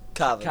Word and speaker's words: Cavan: Is it Cavan? – Cavan. – See, I had a producer that was from the north in the UK, so I learned how to say water. – Yeah, it's pronounced Cavan 100%. – Cavan. – 100% phonetically.